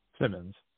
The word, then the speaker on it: Symonds: Symonds.